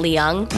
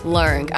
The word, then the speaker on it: Leung: I've been pronouncing it Leung my whole life, but I went to Hong Kong and they were like, oh, your last name is actually pronounced Leung. I was like, what? – Leung. – There's not even an R in my last name. But Leung.